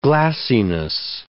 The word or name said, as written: glassiness